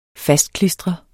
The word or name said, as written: fastklistre